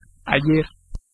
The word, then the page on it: past: /pɑːst/; /pæst/